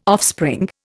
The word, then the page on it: offspring: Indian